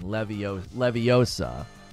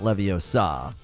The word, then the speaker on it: Leviosa: There's even a GIF of Hermione correcting the pronunciation. Leviosa. Not Leviosa. It's Leviosa. Not Leviosa.